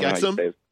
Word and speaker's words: Exum: I think Michael, I don't know how to say his last name, Exum? – Mike Exum? Yeah, Mike Exum.